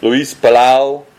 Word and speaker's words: Luis Palau: Luis Palau. You have to say it that way. If you don't say it that way, you're not saying it right.